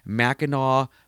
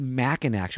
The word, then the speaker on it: Mackinac: I go, but why is it called Mackinac? She goes, well, that's the French pronunciation. And I go, oh, so she was, Mackinac in French is Mackinac.